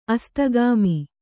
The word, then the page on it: ಅಸ್ತಗಾಮಿ: Kannada